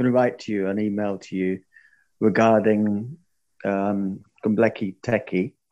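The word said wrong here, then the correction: Göbekli Tepe: Oh, sorry, Gumbleki. I was practicing that before I came on. I knew I was going to murder that pronunciation. Gobekli Tepe.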